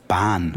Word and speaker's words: Bahn: Zug and also Bahn. – Yeah, like der Bahnhof is the train station, Bahn, that has a silent H. – Yes, gut kennen. Bahn, B-A-H-N.